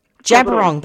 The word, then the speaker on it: Djab Wurrung: Jabiroong, that's how you pronounce it.